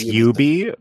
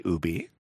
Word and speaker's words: Ubisoft: Technically, it's Ubi, but I do find that a lot of Americans say Ubi.